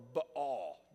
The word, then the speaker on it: Baal: So if you heard in the Old Testament, there's this false god named Baal, or Baal, depending on who you're hearing pronounce that word.